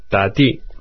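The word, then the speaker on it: ta di (打的): E para pegar ou tomar um táxi, podemos dizer ta di, ta, de a, terceiro tom, e di, de i, di, ta di.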